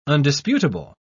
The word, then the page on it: undisputable: British English